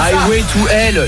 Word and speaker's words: Highway to Hell: Alors je connais un titre et je vais mal le prononcer. C'est I Held to Hell. – Non mais I Held to Hell, non mais n'importe quoi. – Voilà, Highway to Hell, l'autoroute vers l'enfer.